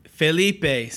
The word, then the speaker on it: Felipe: In good Latin American, it's Felipe. – Felipe.